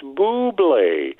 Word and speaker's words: Bublé: And then he stands at the end of the commercial out somewhere and says, "It's Bublé."